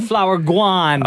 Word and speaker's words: Flourgon: F-L-O-U-R-G-O-N. Florgon? Flourgon? – Like Flower Guan.